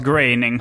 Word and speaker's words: Groening: Man uttalas graining. – Nej, inte i Sverige.